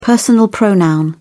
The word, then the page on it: personal pronoun: British English